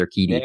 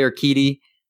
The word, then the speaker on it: Urquidy: I always don't know how to say his name. Urquidy. There we go. I think it's Urquidy. – There. Yep. – Or Jose Urquidy is going to go on the 10-day IL with right shoulder discomfort.